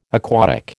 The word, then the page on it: aquatic: American English